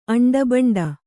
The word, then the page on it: ಅಂಡಬಂಡ: kannada